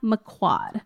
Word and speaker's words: McQuoid: Quad, one syllable. – McQuad. – Well, two syllables, but the second part is one syllable. Wad, McQuad.